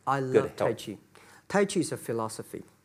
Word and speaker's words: Tai Chi: And You also travel with a Tai Chi. Am I saying that right? A trainer. – Trainer. – Tai Chi? – Tai Chi.